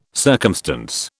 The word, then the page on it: circumstance: British English